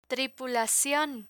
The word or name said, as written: tripulacion